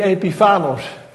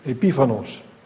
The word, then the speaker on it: Epiphanos: En geen Epiphanos. Ik leg de klemtoon verkeerd. En meneer Vos, die heeft meer talen geleerd dan ik. Dus je zei van, de klemtoon ligt op de I. Epiphanos.